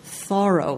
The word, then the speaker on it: Thoreau: It was Thoreau, like borrow.